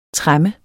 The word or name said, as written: tremme